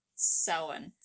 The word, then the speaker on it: Samhain: Yes, Samhain.